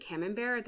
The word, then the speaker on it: camembert: I pronounce it camembert. That's how I've heard it.